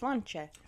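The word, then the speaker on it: sláinte: Looks like it's spelled slainte, but it's pronounced slancha.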